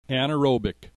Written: anaerobic

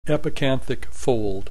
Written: epicanthic fold

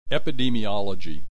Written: epidemiology

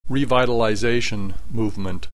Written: revitalization movement

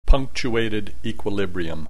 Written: punctuated equilibrium

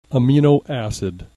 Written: amino acid

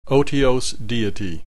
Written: otiose deity

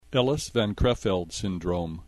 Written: Ellis-van Creveld syndrome

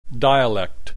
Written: dialect

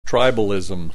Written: tribalism

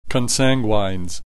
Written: consanguines

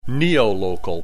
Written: neolocal

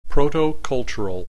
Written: protocultural

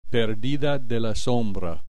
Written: perdida de la sombra

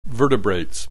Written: vertebrates

